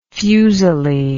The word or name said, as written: fuseli